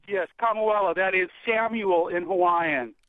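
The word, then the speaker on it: Kamuela: We're going to start with us from Kamauela in Hawaii. Am I pronouncing that anywhere close to right? – You are correct, sir.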